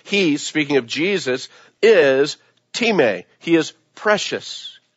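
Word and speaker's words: time (τιμή): It's spelled like time, T-I-M-E, but it's pronounced time.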